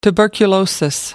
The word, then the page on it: tuberculosis: American English